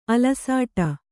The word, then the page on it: ಅಲಸಾಟ: alasāṭa